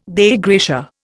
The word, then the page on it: Dei gratia: Indian